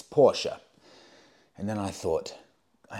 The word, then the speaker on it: Porsche: And it's true, I've been pronouncing Porsche wrong my whole life. It is in fact Porsche. Even Porsche confirm this.